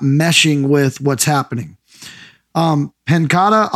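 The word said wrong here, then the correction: Paquetá: I'll tell you right now. Did I say his name right? – Paqueta.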